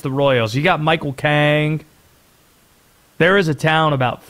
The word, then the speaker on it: King: that is spelled like Michael King's name. K-I-N-G. Do the people from this place in North Carolina say King, North Carolina? No, they do not. Kang. – Kang. – That's it. It's Kang, North Carolina.